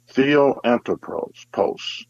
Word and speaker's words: theoanthropos: but another powerful theological term is the theoanthropos, and that is spelled T-H-E-O-A-N-T-H-R-O-P-O-S, theoanthropos.